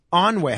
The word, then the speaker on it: ennui: Onway. How do you spell Onway? – E-N-N-U-I. – E-N-N-U-I? – Yes.